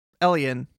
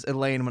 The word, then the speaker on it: Elian: Gwen and Elian, which I always want to pronounce as Elaine when I read it in my notes, I believe he tells them to, like, go on without them.